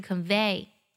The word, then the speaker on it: convey: 需要注意的是呢，原声将 convey 这个单词音准唱错了， convey 最后一个音节读作 vey，不读作 v，不是 convey 而是 convey。